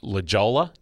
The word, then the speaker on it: La Jolla: So it looks like La Jolla, J-O-L-L-A, but right. It's pronounced obviously in a Spanish.